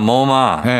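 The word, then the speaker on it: Momma: 근데 스펠링을 보면은 어, M O M M A. 아, 뭐마. 예, 네, 약간 뭐 마마랑은 또 달라요.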